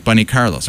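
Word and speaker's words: Bun E. Carlos: And the thing is, I actually wrote it so it would be, you would pronounce it like Bunny Carlos. Everyone like says his name like his first name is Bunny, even though it's Bun E.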